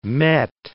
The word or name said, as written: met